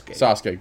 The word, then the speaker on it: Sasuke: Great Sasaki and Did I say his name wrong? I know. – Sasuke. – Great Sasuke.